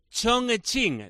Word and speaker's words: Chongqing: El líder de la Superliga China se pronuncia Chongqing. La Q es como una CH suave.